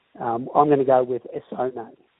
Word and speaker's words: Essonne: I've just put it into pronunciation. It's Essonne. That's how you pronounce it. – Essonne.